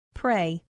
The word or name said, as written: pray